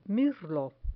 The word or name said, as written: mirlo